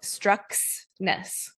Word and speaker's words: Struxness: It's just how it's spelled. Struxness.